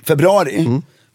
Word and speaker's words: februari: Februari. Februari heter så. – Det, jag kommenterar att det varje gång. – Ja, vad säger jag? – Du uttalar inte U-et.